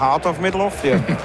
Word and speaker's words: Heart of Midlothian: Rapid, völlig richtig ausgesprochen. Heart of Midlothian.